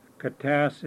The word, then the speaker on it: Katasse: And my English name is Henry Katas Sr. Katas is spelled K-A-T-A-S-S-E.